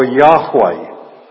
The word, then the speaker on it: Yahweh: His name is I Am, and in Hebrew, if you look up the Hebrew letters, transliterated, they are YHWH. And that is usually pronounced in English as either Jehovah or Yahweh.